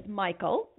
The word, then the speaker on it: Mikal: So this is Michael, and it's spelled M-I-K-A-L.